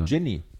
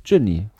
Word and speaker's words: Jinny: Der Name ist Ginny oder Genie? Genie schreibt man ja anders. J-I-N-N-Y. – Ginny. – Ginny. – Ginny. – Ginny schreibt man.